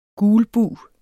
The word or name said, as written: gulbug